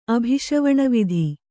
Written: ಅಭಿಷವಣವಿಧಿ

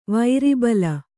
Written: ವೀರಬಲ

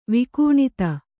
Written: ವಿಕೂಣಿತ

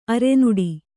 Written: ಅರನಟ್ಟು

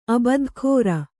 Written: ಅಬದ್ಧಖೋರ

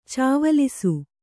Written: ಚವ್ವಲಿಸು

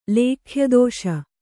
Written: ಲೇಖ್ಯದೋಷ

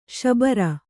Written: ಶಾಬರ

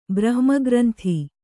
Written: ಬ್ರಹ್ಮಗ್ರಂಥಿ

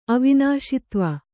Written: ಅವಿನಾಶಿತ್ವ

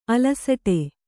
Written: ಅಲಸಾಟ

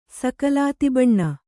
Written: ಸಕಲಾತಿಬಣ್ಣ

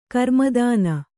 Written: ಗ್ರಾಮದಾನ

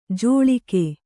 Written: ಜೋಳಿಕೆ

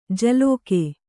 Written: ಜಲೋಕೆ

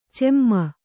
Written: ಚಿಮ್ಮಿ

